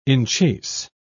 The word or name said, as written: enchase